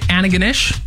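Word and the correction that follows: Antigonish: The way that it says on here, I got the pronouncer up. Let's go with this. – Antaganish. – Antaganish. – I didn't think you said the T. – Oh, you weren't saying the T.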